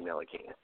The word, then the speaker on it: Ntilikina: Ntilikina, yeah. He's one of my favorite prospects in the draft, you know, with that 7-foot wingspan. – You know, everyone here knows I'm super high on Frank Ntilikina.